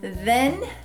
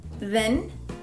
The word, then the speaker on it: then: They're both spelled with a TH, and in this case, you can even feel a vibration in your throat when you say it. Then, then, then, then, then.